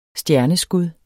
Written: stjerneskud

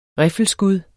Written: riffelskud

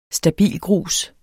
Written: stabilgrus

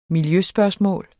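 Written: miljøspørgsmål

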